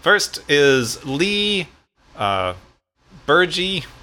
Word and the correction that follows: Berger: I don't know how to say this name, Berger. Yeah. yeah.